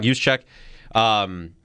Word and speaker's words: Juszczyk: Juszczyk, yep. – Juszczyk.